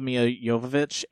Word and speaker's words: Jovovich: So I love Mila Jovovich, and that is actually how you pronounce her name.